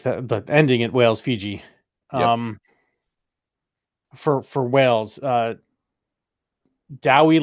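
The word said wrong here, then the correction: Dewi: Lake. No, it's Dewey. Sorry.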